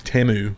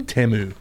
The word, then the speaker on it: Temu: Timu. – I always say it wrong. And people, what is it? – Temu. It's supposed to be. – Tamu? – Temu.